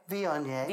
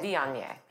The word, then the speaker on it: Viognier: The, uh, pronounce it Vignier. – Viognier. – Viognier. Viognier. – Okay. Uh, V-I-O-G-N-I-E-R.